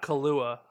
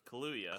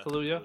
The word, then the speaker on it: Kaluuya: Um, I'm going to butcher his last name, but I it's not Kalua, um, Kilauea, Daniel Kalula, Kaluuya. Kaluuya. Kaluuya.